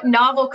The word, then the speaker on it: Novel: So we were all having these conversations internally as a company, talking about, does the name Novel Coworking, which, by the way, nobody pronounced novel correctly. I don't know why. You put a book, like you put Novel Coworking in a book, a novel.